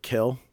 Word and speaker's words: kiln: Because one of the other things Mike Kaysen does, he will occasionally build a giant kiln or kill, because that's how you're supposed to pronounce it.